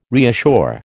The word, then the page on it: reassure: American English